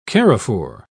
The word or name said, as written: carrefour